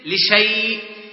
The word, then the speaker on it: لشيء: أمثلة الألف ذات الصفر المستدير مثل لشيء لشيء، نجدها في رسم المصحف هناك ألف لشاء ثم يا، لكن الألف هذا عليه صفر صغيرة يدل على أن هذا الألف لا يتلفظ به وإنما تقرأ لشيء.